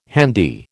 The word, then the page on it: handy: American English